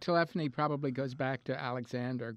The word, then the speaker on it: telephony: Telephony. Yeah, that's how you pronounce it.